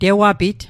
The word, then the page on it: tewapit: Mi'gmaq